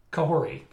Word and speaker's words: Kahori: makes it from that series, um, you know, I think Kalani's probably a big um, uh, chance to get pulled into live action as well. Isn't that Kalani? Is that her name? Did I get the name wrong? Kahori? Kahori, thank you.